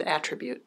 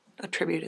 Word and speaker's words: attribute: You said attribute. That's the noun. Attribute is the verb.